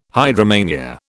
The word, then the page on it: hydromania: British English